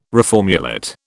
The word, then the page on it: reformulate: British English